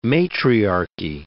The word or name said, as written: matriarchy